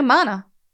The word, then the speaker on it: Mona: No, Mona. Says right here, rhymes with Donna.